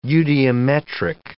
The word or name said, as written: eudiometric